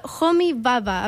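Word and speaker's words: Homi Bhabha: Sí, porque es indio. Homi Baba. Aparte, Baba es como B-H-A-B-H-A.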